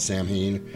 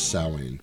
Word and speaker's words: Samhain: Everybody calls it Samhain because that's how it's spelled, but the traditional pronunciation of it is Samhain.